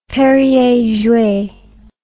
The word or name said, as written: perrier jouet